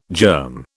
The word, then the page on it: germ: British English